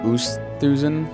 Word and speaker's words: Oosthuizen: Louis Oosthuizen. – Oosthuizen.